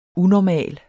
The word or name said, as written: unormal